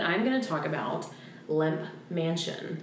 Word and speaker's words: Lemp: And when I say lemp, it's spelled L-E-M-P, just for reference. – It's not lamp. – It's not lamp mansion. – I love lamp. – I love lamp. It's lemp.